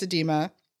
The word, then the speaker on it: edema: Everybody who has a tendency towards edema, I'm pronouncing that right. – Yes, yeah. You are.